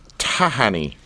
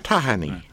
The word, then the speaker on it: Tahani: Tahani. – There you go. – Tahani. – Sort of like tahini, except not.